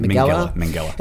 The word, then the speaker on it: Minghella: Minghella.